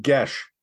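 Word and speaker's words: Geas: It's the spell Gies, or is it Gaze? It's however people... – Gies. – It's pronounced Gesh. – Yeah, Gesh. – Disgust me. It's Gies. – It is pronounced Gesh. It is, I believe, a Celtic word.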